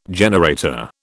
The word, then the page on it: generator: British English